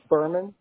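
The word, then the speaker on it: Buhrmann: Berman.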